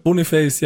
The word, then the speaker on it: Boniface: Boniface, ja, habe ich jetzt gelernt. Ich habe auch immer gedacht, Boniface.